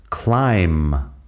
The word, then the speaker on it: climb: También tenemos climb. Climb, que es escalar, tiene la B al final. Si lo pones en pasado también, bueno, la, B no se pronuncia tampoco en pasado, climbed.